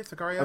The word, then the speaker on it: Sicario: Am I saying that right? By the way, Sicario. – I believe so.